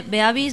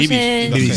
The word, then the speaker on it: Beavis: Beavis y Butthead. – ¿Bibis se va a ir? No, ah. – Sí, pero que no sé, Beavis, no Bibis.